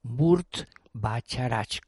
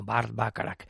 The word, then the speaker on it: Burt Bacharach: Se escribe el nombre Burt Bacharach con Bes. Se pronunciará algo así como Burt Bacharach,